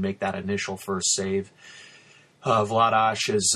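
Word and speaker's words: Vladař: He you know, Darth Vladar. I mean, people say Vladar, Vladash. She's even said Vladar, but really the Czech pronunciation is Vladash.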